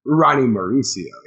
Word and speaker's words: Ronnie Maurizio: I say it with a Z. It's Ronnie Maurizio. Ronnie Maurizio, yeah.